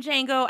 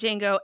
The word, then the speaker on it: Django: I would like to remind our listeners that this dog is named Django after a musician named Django and not Django Unchained. – That's correct. I've never seen that movie. – You haven't? – No, the D is silent.